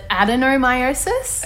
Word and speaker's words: adenomyosis: I have just this week been diagnosed with andimiosis. Did I say that correctly? Andimiosis? – It's such a tricky word. I only just recently learned how to pronounce it, adenomyosis. – Okay, yep, that's definitely better.